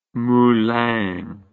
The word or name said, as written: moulin